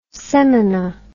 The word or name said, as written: semina